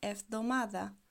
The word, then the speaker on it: εβδομάδα: So when a word is difficult for you to pronounce, you can split it into parts. For example, you can break it down into 4 parts. Ev εβδομάδα. Try to say it slowly, and then you can pair, like you can put the two parts together and the other two parts together. Εβδομάδα. And then you can try to pronounce it all together.